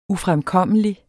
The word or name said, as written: ufremkommelig